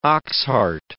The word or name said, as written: oxheart